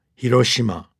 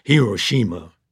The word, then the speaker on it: Hiroshima: It's pronounced very flat, Hiroshima, not this rhythmic Hiroshima.